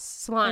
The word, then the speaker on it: Solange: And then her younger daughter's name is Solange. – Yes, Solange.